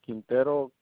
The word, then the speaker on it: Quintero: H-O-S-E-A? – H-O-S-E-A, yeah. – Yes, okay. And what's your last name, Jose? – Quintero, Q-U-I. – How you say, play it again? Spell it again. – Q-U-I. – Q-U-I.